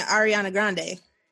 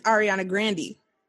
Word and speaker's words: Ariana Grande: You're saying Ariana Grande. – Huh? – Her name is Ariana Grande.